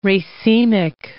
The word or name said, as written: racemic